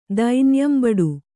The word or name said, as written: ದೈನ್ಯಂಬಡು